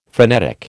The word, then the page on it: phrenetic: American English